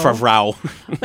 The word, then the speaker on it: Favreau: Favreau.